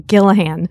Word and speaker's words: Gillihan: Gillihan.